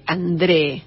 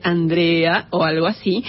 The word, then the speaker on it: Andrée: Entonces, Elfrida André se escribe pronunciaríamos Elfride Andrea o algo así, es nuestra compositora histórica del día de hoy, que nació en Visby, en Suecia, en el año 1841.